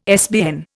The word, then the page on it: SBN: Indian